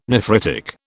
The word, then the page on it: nephritic: British English